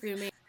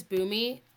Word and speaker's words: Bunmi: Hi you guys, my name is Bunmi. – So Bunmi, I'm sorry, how do you pronounce your last name? – My first name is Bunmi. You don't pronounce the N, and then my last name is Omisore.